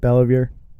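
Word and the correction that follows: Beauvillier: No, it's Bevilier. – Bevilier.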